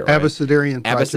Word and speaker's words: Abecedarian Project: Abecedarian Project.